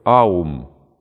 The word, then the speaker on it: Аум: Аум, или как мы обычно помним, это оум мы называем, но он три звука имеет. А-у-м. Аум. Аум. Произносится.